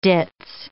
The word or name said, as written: ditz